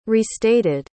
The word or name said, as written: restated